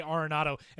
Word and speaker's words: Arenado: Now they say Arenado, and you can hear them catching themselves to make sure that they're saying it correctly.